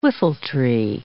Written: whiffletree

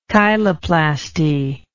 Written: chiloplasty

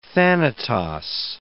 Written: Thanatos

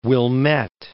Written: Wilmette

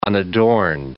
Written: unadorned

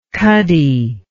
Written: cuddy

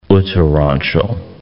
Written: Uttaranchal

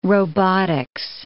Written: robotics

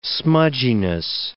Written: smudginess

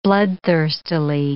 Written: bloodthirstily

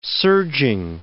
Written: serging

